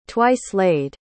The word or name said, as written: twice-laid